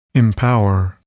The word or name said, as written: empower